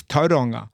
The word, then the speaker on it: Tauranga: You feel a bit silly when you try it because you Tauranga, and you're like, no, it's Tauranga.